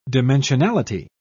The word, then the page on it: dimensionality: British English